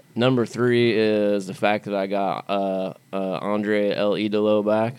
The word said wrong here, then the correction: Andrade: I hope I said that right. – Andrade? – Andrade.